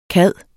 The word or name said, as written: cad